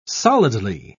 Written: solidly

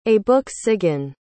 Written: abooksigun

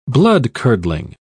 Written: bloodcurdling

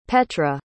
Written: petre

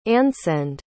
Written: Ancenned

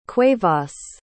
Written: cuevas

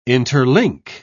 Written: interlink

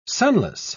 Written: sunless

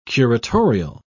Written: curatorial

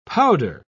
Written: powder

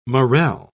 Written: morel

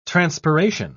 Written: transpiration

